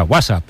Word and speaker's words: WhatsApp: No, peor aún, con acento en la primera. WhatsApp, ¿no?